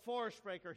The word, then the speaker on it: Vorsprecher: When you add the V-O-R, which is pronounced for, before sprechen, it means to speak for or on behalf of. Jesus is the Vorsprecher.